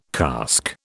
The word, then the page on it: cask: British English